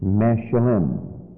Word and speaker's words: meshalim: That particular word in the Hebrew is pronounced Meshalim.